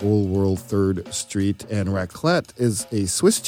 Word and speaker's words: raclette: It's a raclette. Is that how you pronounce it? – Yeah. Oh, yeah, wow. You said it right.